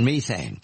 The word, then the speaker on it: methane: If I read correctly, carbon dioxide, the greenhouse gases, is a major concern, but so is methane in the British pronunciation, methane.